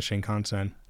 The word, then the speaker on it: Shinkansen: I've taken the Shinkansen.